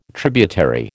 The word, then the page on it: tributary: American English